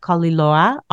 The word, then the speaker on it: Koliloa: My very beautiful, dear friends, Sam and Eva Koliloa.